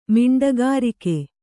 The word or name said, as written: ಮಿಂಡಗಾರಿಕೆ